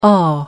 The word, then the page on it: awe: American English